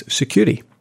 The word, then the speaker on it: Securi: Securi. So it's spelled S-E-C-U-R-I.